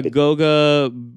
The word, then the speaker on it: Goga: Pacers get Goga. Is that how you would pronounce that? Goga. – Yeah, Goga.